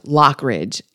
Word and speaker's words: Lockridge: Yeah, it's pronounced Lockridge, and I got it wrong in the actual conversation.